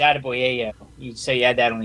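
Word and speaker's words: Adeboyejo: But if you look at his name, and I kind of gave him the nickname Attaboy because his name is Adeboyeyo. That's how you pronounce it.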